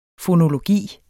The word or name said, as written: fonologi